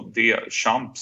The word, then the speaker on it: Duchamp: Han bytte för ett par år sedan namn från eh, John eh, Christian Eriksson till Duchamp i efternamn, alltså D-Champ som han uttalar det på svenska.